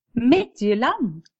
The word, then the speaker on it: Midtjylland: Our next Champions League game against Midtjylland.